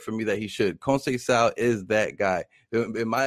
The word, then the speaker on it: Conceição: I think Shakur Stevenson puts it on Conceição and anybody else in that weight class. – Yeah, absolutely, and thank you for reminding me. Conceição. You got to hit that pronunciation.